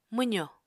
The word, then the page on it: mwzò: Apatani